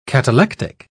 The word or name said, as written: catalectic